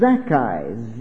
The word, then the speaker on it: Zaccai: Pronounced Zacchai. Z-A-C-C-A-I.